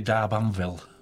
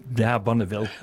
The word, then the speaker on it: D'Arbanville: My Lady D'Arbanville. – D'Arbanville? – Yeah. – Oh, not D'Arbonneville.